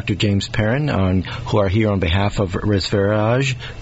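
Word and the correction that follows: Reserveage: Am I saying it right? – Reservage. – There you go.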